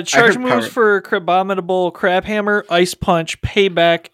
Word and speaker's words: Crabominable: And then Crabominable. Did I say that right? – Crabominable is Bubble, Rock Smash for fast moves, charge moves, Crab Hammer, Ice Punch, Payback, and Power Up Punch.